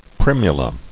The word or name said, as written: Primula